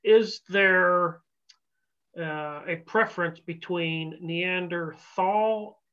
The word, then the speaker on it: Neanderthal: But it's technically, to my knowledge, correctly pronounced Neanderthal, because it is Neander, which is the name of the valley that the first skull was found in, and Tal is valley in German. So Neander Valley, Neanderthal.